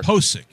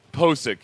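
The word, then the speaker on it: Pocic: And the Ethan Pochick, is it Pochick or Pochick? However you pronounce his name, the center. – Posick. – He's Posick.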